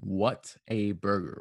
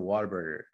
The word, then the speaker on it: Whataburger: But here, I've grown up, anybody is going to say, you want to go to Whataburger? And no one says Whataburger. They say Whataburger. Like almost like you're saying water, but if you say it right, it's not water.